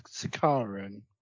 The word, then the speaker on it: Sicarian: Have I pronounced that right? – You have not correct pronounced it right now. It's Sicarian.